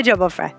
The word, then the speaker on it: Jolma-Fray: It's like Heather Jolma-Fray, you know.